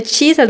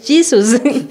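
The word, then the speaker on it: Jesus: Estaba hablando de Jesús, entonces no es chisas, mami, es Jesús.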